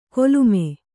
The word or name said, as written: ಕೊಲುಮೆ